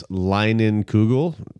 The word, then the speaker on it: Leinenkugel: It's Leinenkugel. – It looks like it's an L, though. It doesn't look like a K, does it? Is it Leinenkugel? – No, it's a K.